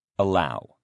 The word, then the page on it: allow: male